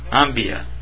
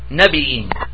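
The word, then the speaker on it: Nabiyyin: How do you say it? Ambiya. But as it's mentioned in the Quran, it's not that way. It says Nabi'in. That's it.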